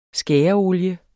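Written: SKÆREOLIE

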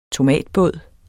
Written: tomatbåd

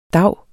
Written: dav

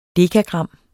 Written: dekagram

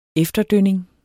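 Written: efterdønninger

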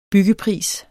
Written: byggepris

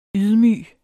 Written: ydmyg

